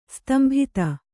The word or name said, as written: ಸ್ತಂಭಿತ